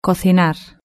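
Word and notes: cocinar: European Spanish

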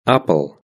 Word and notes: apple: British English

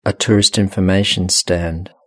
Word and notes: a tourist information stand: British English

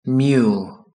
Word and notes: mule: British English

